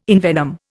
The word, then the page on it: envenom: Indian